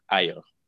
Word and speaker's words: IO: So I know I'll call you do you pronounce it AO for short? Or it's actually IO. – IO, sorry, IO.